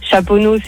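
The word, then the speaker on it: Chaponost: Chaponneau, c'est ST, tu le prononces pas.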